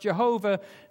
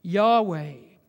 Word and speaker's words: Yahweh: From whence the scholars used to say this should be pronounced Jehovah, now they say it should be pronounced Yahweh.